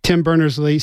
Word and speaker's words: Tim Berners-Lee: And he says, Tim Berner, let me make sure I say his name right again. Tim Berners-Lee says, he's optimistic, and I am too.